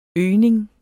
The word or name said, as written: isnet